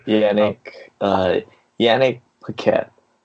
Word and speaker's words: Yannick Paquette: Art was by Vanek Paquette. I'm probably butchering the name. But Yannick Paquette. – Yeah, that sounds a lot better than what I did.